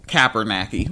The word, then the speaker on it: Kaepernick: But they're talking about Colin Kaepernick.